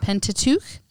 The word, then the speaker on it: Pentateuch: So when I think about the Torah, I think about the Pentateuch. Is that how you pronounce it? – The Pentateuch. – Pentateuch, okay, which is the first five books of the Bible.